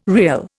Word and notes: rial: Indian

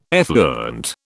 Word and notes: effluent: British English

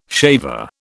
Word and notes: shaver: British English

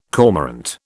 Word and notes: cormorant: British English

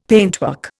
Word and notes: paintwork: Indian

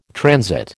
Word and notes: transit: American English

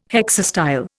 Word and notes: hexastyle: Indian

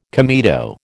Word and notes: comedo: American English

